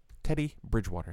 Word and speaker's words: Teddy Bridgewater: Teddy Bridgewater, did I say that right? I felt like I botched that. – No, I think you said it correctly.